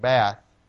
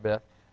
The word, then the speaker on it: Beth: And then he said Beth, I think Bath. So I'll have to remember that, Beth. Bath.